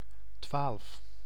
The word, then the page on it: twaalf: /tʋaːlf/